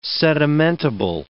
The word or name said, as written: sedimentable